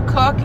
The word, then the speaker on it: Koch: I have her, her name is Sarah Cook, and it's spelled K-O-C-H,